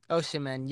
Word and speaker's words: Osimhen: But Victor, I think Osimhen. – Osimhen, yep. – Okay, I was close.